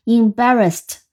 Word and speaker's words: embarrassed: 那实际上，embarrass 是个动词，但反倒它的形容词是常见的啊，动词并不是那么常用，所以它的重音在第二个音节 embarrass，然后加上的 embarrassed，因为后面是一个清辅音，所以这个 ed 它读音读成 embarrassed，embarrassed，我们拼一下，em 是 em，barra。